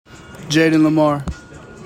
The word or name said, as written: JAYDEN LIMAR